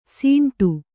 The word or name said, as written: ಸಿಂಟು